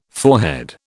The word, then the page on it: forehead: British English